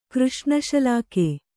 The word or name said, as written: ಕೃಷ್ಣಶಲಾಕೆ